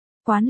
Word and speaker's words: quán: Quán là cái mũ, còn đọc là quan,